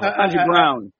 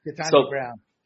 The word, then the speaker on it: Ketanji Brown: Ketanji Brown. – Yeah. Ketanji Brown.